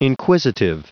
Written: inquisitive

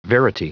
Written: verity

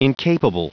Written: incapable

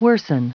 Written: worsen

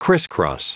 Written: crisscross

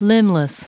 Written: limbless

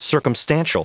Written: circumstantial

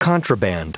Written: contraband

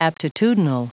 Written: aptitudinal

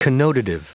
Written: connotative

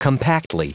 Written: compactly

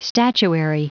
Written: statuary

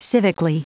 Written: civically

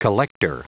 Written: collector

